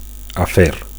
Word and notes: afer: Catalan

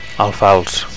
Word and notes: alfals: Catalan

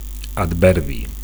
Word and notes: adverbi: Catalan

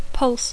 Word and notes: pulse: English

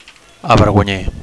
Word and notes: avergonyir: Catalan

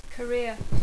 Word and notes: career: English

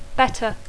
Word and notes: better: English

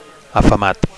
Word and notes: afamat: Catalan

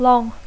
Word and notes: long: English

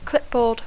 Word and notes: clipboard: English

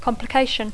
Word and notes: complication: English

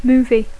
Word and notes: movie: English

